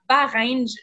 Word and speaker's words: Bahreïn: Euh, vous en avez fait une, une longue euh, qui a eu lieu, comme tu, vous l'avez dit, là, à Bahreïn.